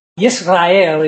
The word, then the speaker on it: Yisrael: You know, Yisrael is the Hebrew for Israel, which we made it Israel.